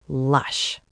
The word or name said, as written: lush